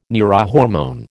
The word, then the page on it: neurohormone: American English